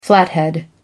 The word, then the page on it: flathead: American English